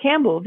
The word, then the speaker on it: Campbell: Campbell, wie die Suppe.